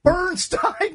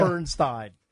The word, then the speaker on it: Bernstein: Bernstein, don't Bernstein. – Say Stein. – Stein would rhyme with a line. – Right, of course it's okay. Bernstein.